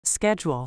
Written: schedule

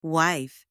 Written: wife

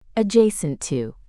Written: adjacent to

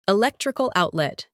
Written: electrical outlet

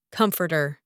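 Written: comforter

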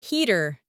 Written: heater

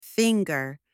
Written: finger

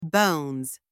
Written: bones

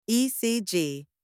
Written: ECG

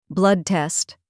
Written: blood test

